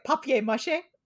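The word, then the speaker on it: papier-mâché: Because I kept pronouncing it papier mache, and she was like, "Stop it!" I have this whole thing where like I that's the real way to pronounce it.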